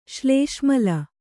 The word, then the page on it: ಶ್ಲೇಷ್ಮಲ: Kannada